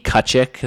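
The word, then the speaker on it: Tkaczyk: J D Kuchyk. That's T K A C Z Y K on all social media.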